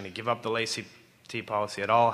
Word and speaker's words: laïcité: I think many would agree that France is a unique example with regards to Muslims living in Europe because of the laïcité policy, I don't know if I'm pronouncing it right, yep.